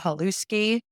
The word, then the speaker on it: Halewski: So my profile is just my name, Paula Halewski. I could probably spell that for you since everybody spelled it wrong. Halewski.